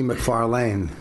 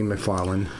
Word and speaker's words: McFarlane: You're pronouncing my name wrong. You know that, right? – Oh, yeah. It's Bonnie McFarlane. Lane. But I added a D. – That's fine. – Bonnie McFarlane.